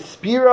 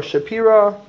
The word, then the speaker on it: Spira: So first of all, the Bnei Yisachar's name was Rev Tzvi Elimelech, I think Spira or Shapira, depending how you want to pronounce it.